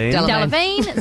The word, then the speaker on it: Delevingne: Delevingne.